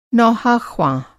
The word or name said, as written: नौहाख़्वां